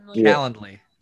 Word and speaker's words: Calendly: and I created a Calendly, I think that's how you pronounce it. – Yeah. – Account. – Calendly. – Calendly.